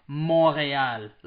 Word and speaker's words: Montreal: Yeah, I gotta say too, I'm French American, so I gotta say it correctly. It's Montreal, ladies and gentlemen.